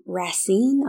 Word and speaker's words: Racine: They do ship them from Racine.